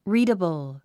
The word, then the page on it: readable: ríːdəbl; リィダボゥ